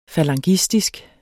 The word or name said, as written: falangistisk